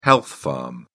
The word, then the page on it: health spa: British English